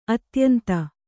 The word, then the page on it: ಅತ್ಯಂತ: atyanta